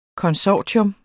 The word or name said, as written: konsortium